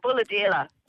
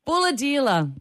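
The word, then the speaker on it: Bulahdelah: Let's go to Balladella. Did I pronounce it correctly? – No. – Oh, you tell me. Where are you from? – Bulladella. – Bulladella. There you go.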